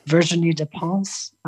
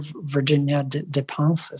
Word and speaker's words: Virginie Despentes: And then there's a book called King Kong Theory by, I'm going to say her name with a terrible accent, Virginie Despentes. The French writer Virginia Despentes is how I would say it with an American accent.